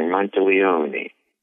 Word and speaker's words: Monteleone: Just think of like an Italian opera or something. Monteleone.